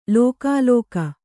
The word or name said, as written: ಲಕಲಕ